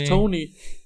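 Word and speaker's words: Toney: Ich finde, ähm, auf welchen Namen einigen wir uns jetzt? Tonney? Äh, wie, wie nennen wir ihn jetzt? – Kadarius Tony. – Tony, Tony. – Also so Tony. wurde er überall ausgesprochen in den USA.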